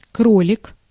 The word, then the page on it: кролик: /ˈkrolʲɪk/; krolik